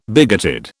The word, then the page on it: bigoted: British English